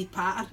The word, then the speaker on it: patter: And you can't pronounce the T's, you need to say patter.